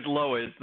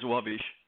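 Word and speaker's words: Lowisz: The American way is Lois. The Polish way is Lovish.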